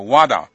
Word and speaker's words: WADA: 嗯哼，那体育仲裁法庭就 CAS 去年 11月宣判之后啊，那在 12月2号，也就是1个多月以前，那世界反禁药组织那它的英文缩写是 WADA，就是发音叫 WADA。那宣布最新的防止运动员作弊的准则，就是要制作运动员的生物护照。那这个 WADA 这个组织公布的这个是准则，包括运动员必须做哪一些定期的检验，而且检验应该如何的登录。